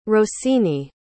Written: rosine

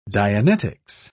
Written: dianetics

